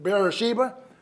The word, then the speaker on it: Beersheba: B-E-E-R. I prefer to pronounce it bear instead of beer, but Beersheba. Okay.